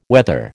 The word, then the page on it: whether: American English